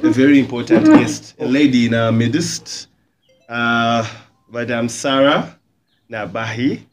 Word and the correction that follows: Nahabi: Nahabi. Oh, sorry, I said it the other way around.